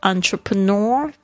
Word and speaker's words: entrepreneur: I applied for, 就是去申请 the entrepreneur visa. 这个 entrepreneur 有点难拼哦，而且发音要特别注意一下那个 EN 发 on 的声音。